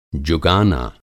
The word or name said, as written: जुगाना